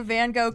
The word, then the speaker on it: Van Gogh: Yeah, this is, you know, this is the Van Gogh casino. Except it's pronounced Van Gogh, so the Van Gogh casino.